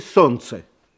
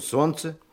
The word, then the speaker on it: солнце: Есть такие слова, в которых некоторые согласные никогда не произносятся. – Почему не произносятся? Они что, неприличные? – Абсолютно приличные. Просто в целом ряде слов они не слышатся. Ну вот, например, что вы слышите в слове «солнце»? – Так и слышим «солнце».